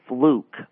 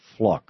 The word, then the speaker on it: Fluke: First of all, I think it's Sandra Fluke, like flute, but with a K. – Okay. – When you mispronounce it the way Limbaugh, I think, intentionally did, you're dangerously close to George Carlin's seven forbidden words. – Yeah, okay. I think I mispronounced it that way in my newscast today, but I understand that it's pronounced like book or look, so it'd be fluck.